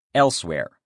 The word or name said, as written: elsewhere